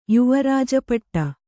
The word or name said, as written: ಯುವರಾಜಪಟ್ಟ